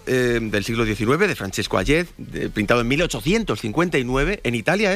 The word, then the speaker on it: Hayez: Vienes a descubrirnos el cuadro El beso de Hayez, se pronuncia, ¿no? – Hayez.